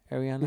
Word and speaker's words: Ariana: Is that how you say her name? Ariana? – Mm-hmm.